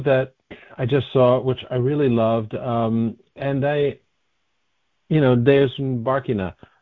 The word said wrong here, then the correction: Deus ex Barkina: Yeah, it's Deus ex Barkina, like Deus ex Deus, Machina.